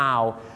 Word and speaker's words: hour: hours ถ้าไปออกเสียงว่า 25 hours นี่นะครับผมฝรั่งจะฟังเป็นนกฮูก 25 ตัวนะครับเพราะ owl เนี่ยออกเสียงว่า owl ไม่ใช่ o นะครับ owl นั่นเองครับดังนั้นครับชั่วโมงต้องออกเสียงว่า hour นะครับ